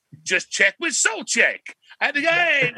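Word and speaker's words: Souček: And we had some, like, my girlfriend's there and his name is pronounced Soulcheck.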